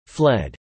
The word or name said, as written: fled